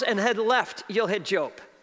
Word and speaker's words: Job: If you have your Bibles, I want you to turn to the book of Job, not Job. Turn to the book of Job, spelled the same.